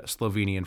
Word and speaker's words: Slovenia: We'll start off with the pronunciation of the country that he comes from, which is, of course, Slovenia, and not Slovenia or Slovenia or something like that.